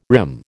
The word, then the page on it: rim: American English